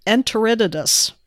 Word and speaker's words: Enteritidis: This very first line here in my next story, Salmonella enteritis. Is that how you say. – That? – Oh, this is my favorite. So I told Stacy the other day, I have switched from my favorite microbial pronunciation of monocytogenes to enteritidis. – Oh, is that what it is? – That's what it is.